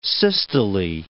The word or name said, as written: systole